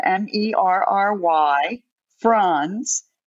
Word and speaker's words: Merry Franz: Merry Franz, F as in Frank, Ronz.